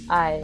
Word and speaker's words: aye: And then Scotland, we don't say yes, we say aye. – I.